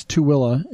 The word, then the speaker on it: Tooele: It is Tooele, but it's Tooele is how we pronounce it. – That's because it was originally named Tooele, T-U-I-L-L-A.